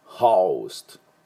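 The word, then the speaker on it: host: HO なんでホストと読みたいところなんですが、この O の音ですね、実はアルファベットの O の音と同じで、あのホストじゃなくてホオウなんだ、ホーストホーストあのバラなんかもローズじゃなくてローズローズだったり、電話もフォーンじゃなくてフォーンフォーンだったりする、その O っていうしっかり O って発音する音です。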